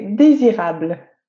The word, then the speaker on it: désirable: So in French, we say désirable.